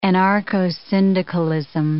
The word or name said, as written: anarcho-syndicalism